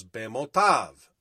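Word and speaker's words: Bemotav (במותיו): And deaths is plural in the Hebrew and it's the oddly spelled phrase from the Hebrew letters Bet Mem Tav Yod Vav, and it's pronounced as Bemotav.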